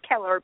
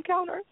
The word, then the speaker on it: Keller: And then I looked at your name, and I'm like, no, it is Keller, but I keep calling him Kellner.